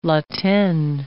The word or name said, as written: La Tene